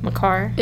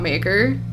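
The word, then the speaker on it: Makar: McCarr. – It is McCarr, not Maker.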